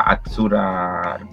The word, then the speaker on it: Azzurra: Azzurra Roma, creo que se pronuncia así, ¿no? Sí, Azzurra, pero Azzurra.